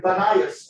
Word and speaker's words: Banias: Arabs don't pronounce the P very well, so it became Banias or Banyas.